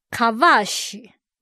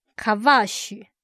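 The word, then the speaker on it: kavash (כבש): 这个字它的字根念作 kavash kavash，一个 kaf 一个 bet 一个 shin，意思在圣经有践踏、做奴婢、凌辱、治理、驯服、制服这些含义。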